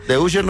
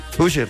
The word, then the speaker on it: Usher: The Usher no se dice. Es que el inglés no se me da. No, Usher.